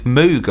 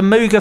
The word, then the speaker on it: Moog: Moog was supposed to rhyme with Vogue. That was where it came from. He was like, that's how you're supposed to pronounce it. – But the Mooga Fooga doesn't work as the Mooga Foga.